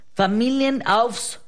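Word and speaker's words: Familienaufstellung: Familien Aufstellung.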